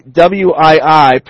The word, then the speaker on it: Wii: Nintendo's new video game system, the WII, pronounced Wii, comes out this fall and introduces a new way to play video games by using stupid.